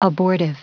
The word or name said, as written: abortive